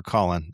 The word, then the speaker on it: Colin: I called you Colin or Colum. – Colum rhymes with Gollum. Yeah. That's a little weird. – Sorry, my bad. – I prefer Colin.